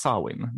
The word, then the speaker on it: Samhain: Eh, det, det, uttalas Samhain, men det stavas Samhain.